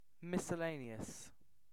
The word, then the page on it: miscellaneous: British English